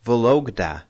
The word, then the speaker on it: Vologda: So you see a Kremlin in a town called Vologda.